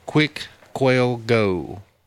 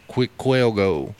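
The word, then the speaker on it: Cuicuilco: So, you know, I'm sure there's some rolling of the Q's and R's, but Quicquelgo. And for those that want to look this up, it's spelled C-U-I-C-U-I-L-O.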